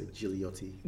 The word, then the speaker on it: Gigliotti: You pronounce it with a second G silent, so you say Gigliotti.